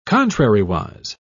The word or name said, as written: contrariwise